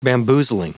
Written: bamboozling

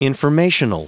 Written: informational

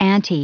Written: ante

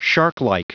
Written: sharklike

